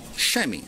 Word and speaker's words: chamois: and the how do you pronounce that last word? Chamois. The same thing I clean my car with.